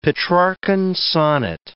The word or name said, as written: Petrarchan sonnet